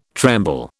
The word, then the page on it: tremble: British English